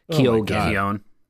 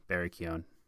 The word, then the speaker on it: Keoghan: Keoghan. – Oh my god, how do you say it? – Keoghan. – Really? – Yeah. – Silent G-H? – Yes, Barry Keoghan.